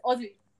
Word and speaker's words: Aussie: It's Aussie. – Aussie. – Aussie. – Yeah. – It's like Mozzie.